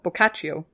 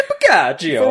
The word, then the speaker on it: Boccaccio: No, Boccaccio. There we go.